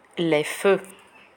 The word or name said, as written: les feux